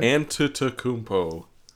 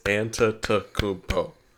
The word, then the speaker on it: Antetokounmpo: Dang it. It's 5 syllables. Antetokounmpo. – Okay, now say it fast. – Antetokounmpo.